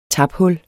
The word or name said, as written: taphul